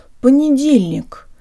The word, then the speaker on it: понедельник: Hey guys, today you learn how to pronounce the word понедельник, or Monday. Not all the letters in this word are pronounced the way we say them in the alphabet. The O here sounds like a schwa.